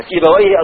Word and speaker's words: سيبويه: وقد حكي ان ابا عبيد بن حربويه، المحدثون يقولون حربوية وسيبوية وعمروية، لكن اللغويين يقولون حربويه وسيبويه وعمرويه ونفطويه وهكذا، ورهوي.